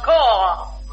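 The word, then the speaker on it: corps: and I calls out the corpse." – "Pardon me, that word is pronounced core."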